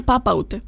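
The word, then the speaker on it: Papaoutai: Το Αμπαούτε λέει. – Ωραία, ποιος τραγουδάει. – Και το λένε Παπαούτε.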